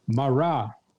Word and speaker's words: marah: This concept of a looking glass comes from the Hebrew word marah. Marah, which is spelled M-A-R-A-H. M-A-R-A-H, marah, and it means an appearance.